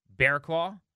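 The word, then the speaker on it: Barraclough: And they went and got Kyle Bearclaw. Now, his name, Charlie and Dave style, he's spelling with the bell here, is spelled funny. It's B-A-R-R-A-C-L-O-U-G-H, Bearclaw. – Ding.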